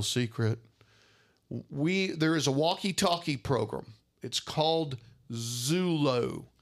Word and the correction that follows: Zello: Z-U, let me spell it for you. – Zello. – Zello. – I call Not Zulu.